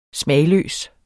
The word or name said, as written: smagløs